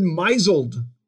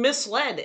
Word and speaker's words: misled: She pronounced that word misled. – Ooh, wait a minute. She has been misled as how that is supposed to be pronounced. – But it sounds really bad if you say he misled me. Like, you should call the police.